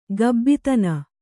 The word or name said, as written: ಗಬ್ಬಿತನ